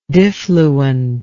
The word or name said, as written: diffluent